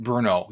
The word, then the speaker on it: Brno: In Brno, right? Is that how you say it? How do you say it? Brno, yeah, okay.